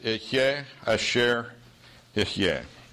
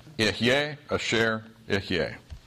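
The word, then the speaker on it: Ihyeh Asher Ihyeh (אֶהְיֶה אֲשֶׁר אֶהְיֶה): In Hebrew, it's pronounced Ihyeh Asher Ihyeh. Ihyeh Asher Ihyeh.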